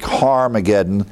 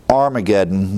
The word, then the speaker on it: Harmageddon: And then a staging area, as Fruitenbaum is depicted, the northern Israel, the valley of Megiddo, where we get the word rough breathing in the Greek, Harmageddon. It's actually you have the alpha there beginning the word, but we add an H because of rough breathing. Armageddon, we normally pronounce it that way.